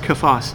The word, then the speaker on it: Cephas: You see, Paul in this letter, he refers to Peter as Cephas, or probably more appropriately pronounced Cephas, which is the name Paul most commonly uses in his letters.